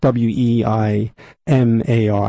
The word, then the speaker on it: Weimar: But they had, after World War One, they had the Weimar Republic, W E I M A R. I guess you could call it Weimar if you're going to use the correct pronunciation.